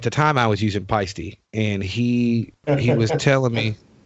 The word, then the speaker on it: Paiste: By the way, just for the drummers that are listening to this, it is fucking pronounced Peisty. Just FYI. If you don't believe me, go to their website. They have an actual pronunciation on the website.